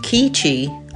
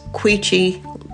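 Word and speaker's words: Quechee: Queechee is often pronounced Keechee. It is Queechee, that Q-U sound as in quick, not Q-U as in French way, K.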